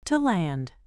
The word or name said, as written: to land